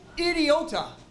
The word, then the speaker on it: idiota: I-D-I-O-T-A. Idiota.